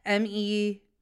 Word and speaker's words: Mejadra: But Mejadar, it's Mejadra.